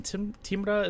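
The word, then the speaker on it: Timrå: It might have been Timra is how you pronounce it.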